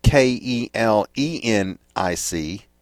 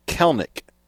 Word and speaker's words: Kelenic: But as far as the position players, the only new position player they have is Jarred Kelenic, K E L E N I C. It's Kelenic, but it's pronounced Kelnick with the E, second E being silent.